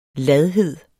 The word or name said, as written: ladhed